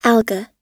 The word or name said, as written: alga